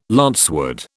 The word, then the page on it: lancewood: British English